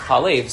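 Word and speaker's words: Chalev (חלב): Manda Amar Yesh in the Mikra obviously is pronounced Chalev, so it's Chalev, it means milk, right?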